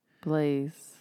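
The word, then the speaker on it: Blaise: It's spelled B-A-I, B-L-A-I-S-E. – Blaze.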